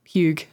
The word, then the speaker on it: hygge: Like hygge? – Hygge.